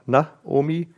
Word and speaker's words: Naomi: Der hatte eine Frau, Naomi, muss man aufpassen, dass das richtig aussprechen, sonst denkt man, du sprichst mit deiner Oma, Naomi, also Naomi heißt sie die angenehme oder meine liebliche Freude.